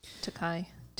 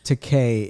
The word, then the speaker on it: Takei: Takei is correct. Takai, I think, means expensive. Huh. – That's uh, according to him. – That's what he said. – Oh, okay. Well, I'll, I'll take it from his words.